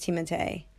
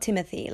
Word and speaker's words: Timothée: And actually, his name is pronounced Timothée, not Timothy. Like I've been saying all my fucking life, Timothée Chalamet.